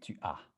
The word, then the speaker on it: tu as: The S is silent. Tu as.